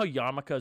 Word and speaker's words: yarmulke: Is spelled, it's Y-A-R-M-U-L-K-E. – Yarmulke. – I thought it was like Yamaha with a K.